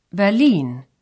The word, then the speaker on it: Berlin: In German, it's pronounced Berlin.